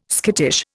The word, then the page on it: skittish: Indian